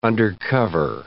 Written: undercover

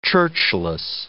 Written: churchless

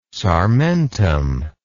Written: sarmentum

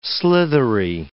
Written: slithery

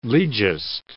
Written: legist

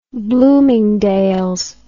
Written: bloomingdales

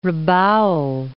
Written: Rabaul

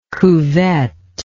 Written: cuvette

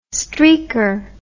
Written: streaker

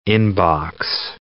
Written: in-box

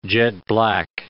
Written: jet-black

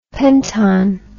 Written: penton

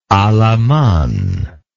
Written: alaman

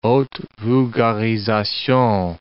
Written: haute vulgarisation

